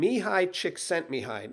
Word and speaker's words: Csikszentmihalyi: And there was, there is a recognized, I think he's a psychologist actually, named Mihaly Csikszentmihalyi. Now, if you look at that in writing and try to pronounce it from what you see in the letters, you're never going to get that.